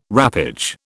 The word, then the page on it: wrappage: British English